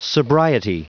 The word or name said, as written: sobriety